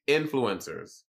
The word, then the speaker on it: influencers: When I think of the word influence, I say influence, not influence. – Influence. My accent is on in. Influencers.